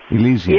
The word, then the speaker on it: Elysium: E-L-Y-S-I-U-M. Elysium.